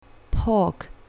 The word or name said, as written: pork